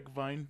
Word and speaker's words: Reichwein: No, but it's W is pronounced like V's. – Is it? – Reichwein, yeah.